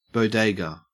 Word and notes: bodega: Australian English

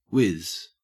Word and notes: wiz: Australian English

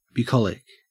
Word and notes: bucolic: Australian English

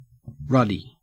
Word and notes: ruddy: Australian English

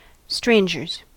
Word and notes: strangers: American English

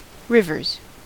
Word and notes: rivers: American English

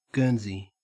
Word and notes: guernsey: Australian English

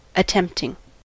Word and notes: attempting: American English

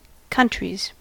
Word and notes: countries: American English

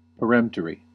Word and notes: peremptory: American English

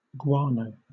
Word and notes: guano: British English

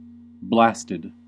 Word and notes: blasted: American English

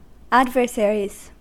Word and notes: adversaries: American English

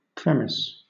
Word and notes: premise: British English

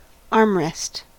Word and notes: armrest: American English

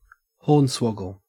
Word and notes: hornswoggle: Australian English

